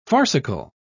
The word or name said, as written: farcical